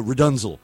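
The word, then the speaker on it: Rdnzl: And then after that, it goes into Rudunzel, which is spelled R-D-N-Z-L. R-D-N-Z-L.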